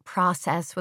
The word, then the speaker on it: process: He says process with a long O.